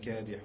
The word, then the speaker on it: كادح: إنك كادح إلى ربك كادحا كادح le ح est plus profond إن شاء الله يعني.